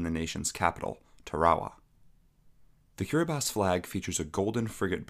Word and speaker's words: Kiribati: Although the country is pronounced Kiribati, it is spelled Kiribati.